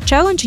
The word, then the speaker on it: челендж: Воно пишеться з однією літерою, з наголосом на першу літеру E. Звичайно, челендж, як чуємо, так і пишемо.